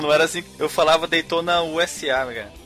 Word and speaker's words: Daytona USA: Peraí, peraí, vocês estão pronunciando, vocês estão pronunciando errado. – Qual é que é? – É Daytonausa. – Daytonausa. – Isso.